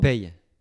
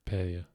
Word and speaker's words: Peille: Yeah, and that's Pia. Is that how you pronounce it? How do you pronounce the town you're from? – Pei. P-E-I-L-L-E. – Pay. – Yeah.